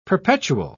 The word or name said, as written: perpetual